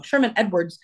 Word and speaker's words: Sherman Edwards: And it was with approval of Sherwin Edwards, oh man, I've said that wrong, Sherman Edwards' estate.